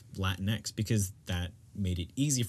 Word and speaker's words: Latinx: Just Latinx. – But you would just, like, say the X at the end.